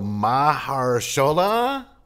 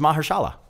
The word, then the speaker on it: Mahershala: So Mahershala? – You fucked it up. – How do you say it then? – You're racist. – Fuck you. You say his name. – Let me see it. – Here it is. Here it is right here. Say that. Ooh. Yeah, say that. – Oh, it's just Mahershala.